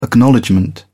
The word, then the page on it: acknowledgment: British English